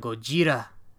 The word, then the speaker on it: Gojira: We're going to be entering the Godzilla dimension, or it's actually pronounced in Japan, the Gojira dimension.